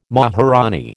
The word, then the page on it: maharanee: American English